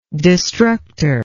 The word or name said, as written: destructor